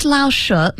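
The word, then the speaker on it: Lao She: The second Laoshe, make sure I'm pronouncing that correctly, Brian.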